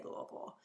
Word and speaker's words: Louisville: I actually currently live in Lexington, Kentucky, and I graduated from the University of Louisville in Louisville, Kentucky, and I am saying the correct way to say Louisville. It's not Louisville or any other way that you see people on TV saying it is Louisville, Kentucky.